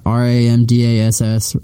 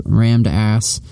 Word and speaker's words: Ram Dass: R-A-M. – RAMDASS, rammed ass, underscore rookie card, but it's actually pronounced Ram Dass.